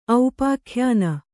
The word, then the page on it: ಔಪಾಖ್ಯಾನ: aupākhyāna